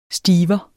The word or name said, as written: stiver